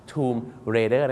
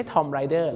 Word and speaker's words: Tomb Raider: ทูม Raider นะครับไม่ใช่ทอมไรเดอร์